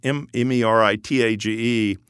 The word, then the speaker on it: Meritage: And incidentally, it's Meritage, and it's not the French pronunciation, so it's Meritage.